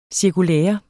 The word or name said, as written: cirkulære